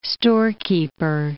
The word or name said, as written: storekeeper